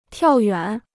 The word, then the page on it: 跳远: tiào yuǎn